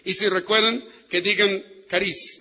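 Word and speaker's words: caris (χάρις): O se escribe charis, pero es caris.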